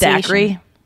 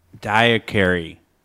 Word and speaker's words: daiquiri: The Fanthrax where the person mispronounced daiquiri. – Yeah. – Was it daiquiris? Because it had an S at the end? – Diacary. Or something like, that. Like daiquiri. Yeah. Or like diacary.